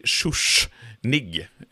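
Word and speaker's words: Schuschnigg: Ah, eh, Men sen så efterträds han av Kurt von Schuschnig. Eh, och, Bra uttal.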